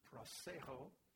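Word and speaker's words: prosecho: It is the Greek word proseho. It is spelled P-R-O, so you can already see where it's going. P-R-O, pro, meaning with or for. Seho, S-E-C-H-O. So it comes from two words, the word which means to look ahead, to go with something, and then mind.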